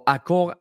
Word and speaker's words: Accor: Imorgon är det PFL i Paris på Accor Arena. Uttalar det rätt, Filip? – Det tycker jag.